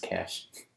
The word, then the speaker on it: cache: Um, this might be even better than Jocasta Nu's Cache. Pretty amazing? – It's just pronounced cash.